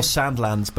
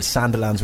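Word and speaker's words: Sandilands: Kyle Sandlands, but your friend said Sanderlands when you were younger and you said it wrong.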